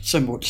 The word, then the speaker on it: Semut: Is that how you pronounce it? Semut? – Semut. – Yeah. Semut.